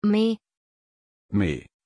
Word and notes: May: German

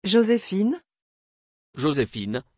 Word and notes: jozefine: French

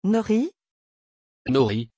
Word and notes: Nori: French

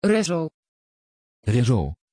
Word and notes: Rezzo: Dutch

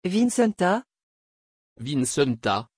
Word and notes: vincenta: French

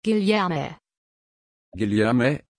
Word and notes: guilherme: German

